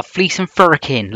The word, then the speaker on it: Fleece and Firkin: Yeah, that's how you say it in Bristol. The Fleece and Firkin, like, me lover.